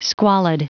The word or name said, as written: squalid